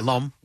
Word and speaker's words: L'Homme: Is it L'Homme? – Yeah, L'Homme. – L'Homme. – One syllable, yeah.